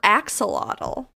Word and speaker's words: axolotl: I think we're saying it wrong, but... – Axolotl.